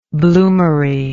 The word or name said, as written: bloomery